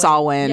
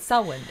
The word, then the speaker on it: Samhain: to it's pronounced Samhain. – Yeah, it's Samhain.